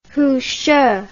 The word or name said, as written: Hushi